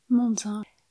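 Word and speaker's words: Mondzain: Et pour cela, on trouve partout autour de la collective une jolie citation d'une philosophe française, Marie-Josée Monzin, comment ça se prononce ? – Monzin.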